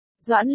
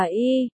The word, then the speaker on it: Doãn: Tống tiên sinh, cái chữ kia không đọc là y, đọc là doãn trong phủ doãn, không phải là y trong y lợi.